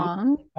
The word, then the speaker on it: Huang: Huang.